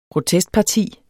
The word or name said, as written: protestparti